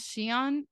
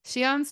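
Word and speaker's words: Siahn: We don't know, because his LinkedIn says he's the CEO, even though he's not the CEO, but his name is Shion, or I believe that's how it's pronounced. It's S-I-A-H-N.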